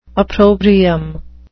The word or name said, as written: opprobrium